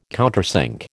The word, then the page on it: countersink: American English